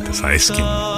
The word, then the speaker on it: Saeskin: Sí, Saeskin. Y así se pronuncia y se acentúa, ¿cierto? – Tal cual.